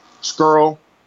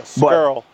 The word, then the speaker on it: Scurll: Scurll. I think that's how you say it, because he said that people mispronounce it all the time now, so I'm trying to say it the right way.